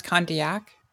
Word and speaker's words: Condillac: It's pronounced Condillac.